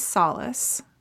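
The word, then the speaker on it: Salas: Mine is Solace, which is pronounced like the word, S-O-L-A-C-E, but it's not spelled that way. But my last name is a palindrome, which I really like because it's S-A-L-A-S.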